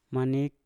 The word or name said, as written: manip